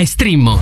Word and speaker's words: Streammo: Entra adesso nel mondo di Strimmo, vai su strimmo.it, si scrive Streammo o scarica l'app.